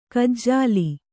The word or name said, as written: ಕಜ್ಜಾಲಿ